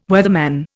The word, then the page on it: weatherman: Indian